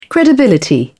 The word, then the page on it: credibility: British English